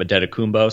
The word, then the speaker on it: Antetokounmpo: Steve Croft pronounced his last name Antetokounmpo, so shout out to Steve Croft from 60 Minutes for that.